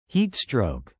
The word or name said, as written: heatstroke